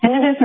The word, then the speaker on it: tinnitus: And now you're saying tinnitus, or because I'd always heard it as tinnitus, but I guess I want to say it correctly. Is it tinnitus? – They're both correct. Tinnitus or tinnitus. – Oh, it is. – They're used interchangeably.